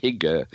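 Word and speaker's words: Hygge: I believe it's pronounced Higa,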